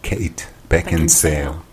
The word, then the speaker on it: Beckinsale: I thought there was a D in there. – No. – No? – Mm-mm. – Kate Beckinsale. Anyway.